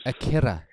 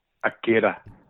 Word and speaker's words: Akira: Akira. You got to roll that R. – From the Blues. – Yep. – Akira.